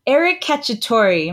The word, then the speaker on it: Eric Cacciatore: Eric Cacciatore, am I pronouncing that correctly? – You are.